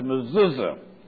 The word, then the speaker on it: mezuzah: And the word looks something like this. M-E-Z-U-Z-O-T-H. Pronounced mezuzah. And they put these on the doorpost.